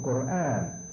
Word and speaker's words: Quran: So you have to roll that tongue like the Spanish do. Quran. It's called Quran by the Muslims, and that's the proper Arabic pronunciation, Quran. That book,